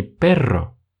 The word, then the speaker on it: perro: Есть очень похожие два слова – pero и perro. Казалось бы, мы всего лишь усилили немного произношение буквы R, однако смысл полностью поменялся. Если pero это слово означает но, то тогда perro означает собака.